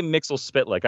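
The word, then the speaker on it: Mxyzptlk: How do you say it? Say it again. – I say Mixel Spitlick. I said it wrong when I said it the first time.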